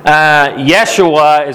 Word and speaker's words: Yeshua: Yeshua is actually the Hebrew pronunciation, which is a variant or where we get the name Joshua from.